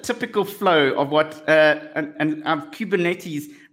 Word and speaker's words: Kubernetes: With orchestration, typically this component is an off-the-shelf management such as Kubernetes.